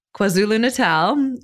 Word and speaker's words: KwaZulu-Natal: Takes place in, I'm going to pronounce this province right, South Africa in KwaZulu-Natal, South Africa. – Got it right.